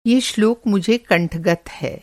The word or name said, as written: कंठगत